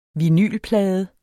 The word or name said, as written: vinylplade